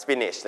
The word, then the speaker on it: spinach: คำนี้ออกเสียงว่าเป็นเสียงอีนะครับ spinach นั่นคือผักโขมนั่นเองนะครับ so we use future simple tense นะครับ will and infinitive verbs here okay she will prepare scrambled eggs with spinach so can you tell me what's infinitive verb is Can you tell me what infinitive verb is in this sentence?